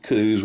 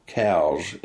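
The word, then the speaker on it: Coues: But it was through the writing of O'Connor that I learned about the Coos white-tailed deer. And actually, when you get right down to it, it probably should be pronounced properly cows, because Dr. Elliot Cowes was a surgeon,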